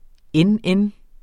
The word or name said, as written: NN